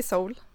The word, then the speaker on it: Seoul: Jag säger Seoul.